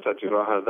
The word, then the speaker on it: Satyagraha: Is that how you say it? – Yes, Climate Satyagraha.